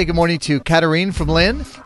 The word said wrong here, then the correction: Katharine: Did I get the pronunciation correct? – It's Katharine, but that's okay. – Katharine. Okay, Katharine. I want to get it right.